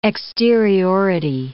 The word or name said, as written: exteriority